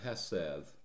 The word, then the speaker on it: hesed: One of the most important words in the Hebrew Bible is a word that is pronounced in the Hebrew hesed, and it's the word mercy in the text before us.